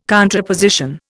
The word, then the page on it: contraposition: Indian